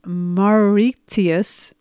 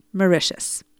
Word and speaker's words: Mauritius: So I just want to let you know that I did a little bit of homework and learned that the country that I called Mauritius is actually pronounced Mauritius, and it's an island.